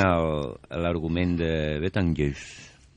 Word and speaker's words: Betelgeuse: Sí, de l'estrella Betgus. – Betelgeuse. – Betelgeuse.